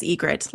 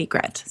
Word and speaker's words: Ygritte: John definitely says Ygritte, like the bird. And it's hard for me because in my head when reading the book, I always said Ygritte.